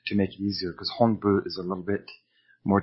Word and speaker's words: Honbu: Most people will pronounce this as Honbu, but it is really pronounced Honbu. And if you can't see the difference there, what I'm referring to is the H-O-N, as in November, or H-O-M, as in Mike.